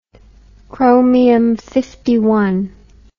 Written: chromium dioxide

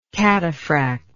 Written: cataphract

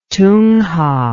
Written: tunja

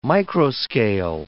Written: microscale

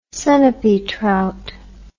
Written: Sunapee trout

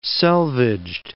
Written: selvedged

